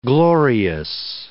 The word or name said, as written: glorious